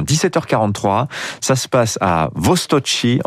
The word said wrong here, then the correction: Vostochny: en Russie, je le prononce bien. – Lance... Presque bien, presque Vostochny.